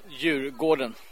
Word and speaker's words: Djurgården: which I believe is pronounced Djurgården, but it's not pronounced Djurgården. How is it pronounced? – Djurgården. – Exactly.